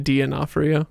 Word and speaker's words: D'Onofrio: Did just say D'Onofrio? – D'Onofrio. – Okay. – That is how he pronounces his name.